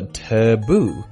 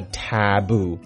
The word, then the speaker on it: tattoo: 纹身这个字可以一起赶快来学习一下，它叫做 taboo。 Taboo, T-A-T-T-O-O, T-A-T-T-O-O, 记住双写 T, 双写 O, 发音记住了，英国人念作 taboo, 而美国人念作 taboo, 重音都在第二个音节。